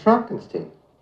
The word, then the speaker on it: Frankenstein: No, it's pronounced Frankenstein.